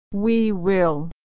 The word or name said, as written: we will